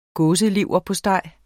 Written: gåseleverpostej

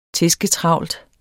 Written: tæsketravlt

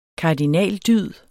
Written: kardinaldyd

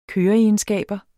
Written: køreegenskaber

